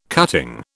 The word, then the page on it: cutting: British English